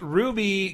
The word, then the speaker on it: RWBY: or as those of you who aren't familiar with the anime would probably pronounce it, seeing it on Steam or PSN, RWBY Grim Eclipse, because it looks like a big acronym.